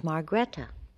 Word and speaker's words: Margrethe: My Christian name is Margrethe, which is a Margaret, is the Norwegian Margaret. It's spelled M-A-R-G-R-E-T-H-E. – See, now, I never knew that. Margrethe. Something I just learned.